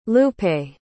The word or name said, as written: lupe